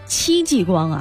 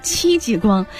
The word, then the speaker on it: 戚继光: 今天我们要聊聊戚继光啊，其实我刚才发音还错了，戚继光。